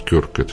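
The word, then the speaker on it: Kirk: Вот Рональд Кирк, которого, вы извините, Кёрк, он правильно произносится, который, я привык его Кирк произносить,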